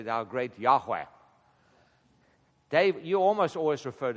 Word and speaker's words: Yahweh: But the correct pronunciation almost for sure is Yahweh.